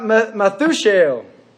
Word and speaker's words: Methushael: And Mahujael begot Methushael. That is how you say that.